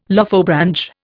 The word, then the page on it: Lophobranch: Indian